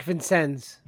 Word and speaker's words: Vincennes: Is it Vicenus? Is that was that what they were saying it? Jack Vincennes. – Vincennes, that's what it was.